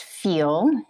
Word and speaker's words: PHEAL: So it's pronounced FEAL, and that is intentional.